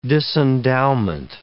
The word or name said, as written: disendowment